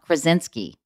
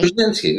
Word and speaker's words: Krasinski: Because you were at Emily Blunt and Krasinski, right? – Krasinski.